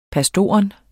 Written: pastor

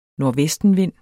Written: NORDVESTENVINDE

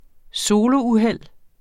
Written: solouheld

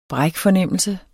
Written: brækfornemmelse

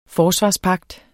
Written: FORSVARSPAGT

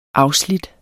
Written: afslidt

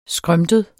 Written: skrømtet

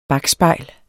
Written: bakspejl

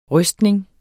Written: rystning

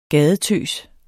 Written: gadetøs

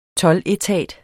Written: toldetat

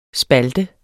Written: spalte

